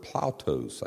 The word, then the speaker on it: ploutos: He used the word plautos, I think it's pronounced, P-L-O-U-T-O-S.